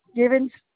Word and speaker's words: Givens: Mabel Givens.